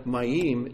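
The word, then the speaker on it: Mayim (מים): The letter Mayim is two syllables.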